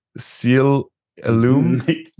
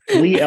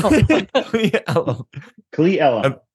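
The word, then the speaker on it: Cle Elum: You have your, I'm going to say this wrong, Seal Illum. – You did say it wrong. – Did I get it right? – No. – Okay, I didn't think. How do you say that? – Klee Elum.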